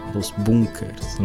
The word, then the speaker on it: Los Bunkers: Sério, durante um bom tempo, as duas únicas bandas do Chile eram Los Banques, que a gente trazia, claro, e o Defuntos Correia. – Que a gente insiste pronunciar errado de acordo com eles, né? – Sim. – Los Bunkers.